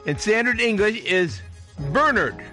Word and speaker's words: Bernard: The first syllable rhymes with fur or sir, burr. Second syllable rhymes with bird or word, nerd. So Bernard. The emphasis stress in the place in the second syllable, Bernard, is confusing all of us, isn't it? – Today's saint is Saint Bernard of Clairvaux.